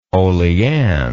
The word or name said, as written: olean